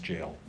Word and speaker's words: Gaol: G-A-O-L is pronounced jail.